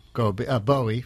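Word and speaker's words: Gbowee: Brand newly minted Nobel Peace Prize winner, Lima Bowie, forgive me, it's G-B-O-W-E-E. – Typical African name. – With the silent G at the beginning, the good old silent G.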